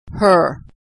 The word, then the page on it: her: American English